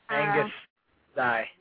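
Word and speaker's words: Agnus Dei: It's called, I don't know how to pronounce it, Angus Day. – Angus Die. – Yeah, okay.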